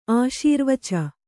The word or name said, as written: ಆಶೀರ್ವಚ